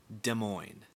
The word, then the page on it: Des Moines: /dəˈmɔɪn/